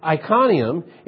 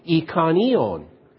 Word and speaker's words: Iconium: So he flees what I was always told is Iconium, and I found out it's actually pronounced Iconion.